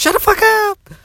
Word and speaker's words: shutdafukup: And today I want to talk about Juicy J's hashtag shut the fuck up, which is pronounced shut the fuck up.